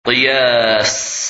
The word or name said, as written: Qiyas